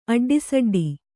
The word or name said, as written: ಅಡ್ಡಿಸಡ್ಡಿ